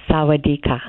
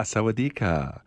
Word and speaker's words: sawadika: Sawadika. – Ah, sawadika. Sawadika. – I could tell you, for a female speaker, it's sawadika.